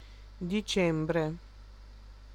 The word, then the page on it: joulukuu: /ˈjou.luˌkuː/